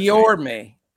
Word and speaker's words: Guillorme: Luis Guillorme, I believe that's Guillorme. Correct. What did I say, Guillermo? – Guillermo, I think. – I didn't say pronounce the L.